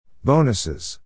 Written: bonuses

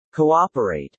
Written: cooperate

